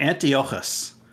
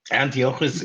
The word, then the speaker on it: Antiochus: Not sure if I'm pronouncing that correctly. – That was perfect, John. Perfect. – Yes. – Antiochus. – Thank you. – In the show, they actually pronounce it a little differently, but Antiochus is the way that it is pronounced.